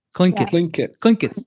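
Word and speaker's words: Tlingit: The guy's talking about the Tlingits? How do you say that? T-L-I-N-G. – Clinkets. – He's saying, you know that thing you guys do? Clinket. – Yeah, okay. Clinket. Got it.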